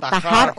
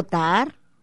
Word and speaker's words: Tahar: Bueno, se escribe T-A-H-A-R, no sé si sea Tajar o Tar. – Yo prefiero decir Tajar.